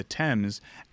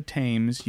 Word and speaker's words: Thames: Thames? As Corden lets us know, not the Thames. You can't say Thames because it rhymes with Ames, the Thames.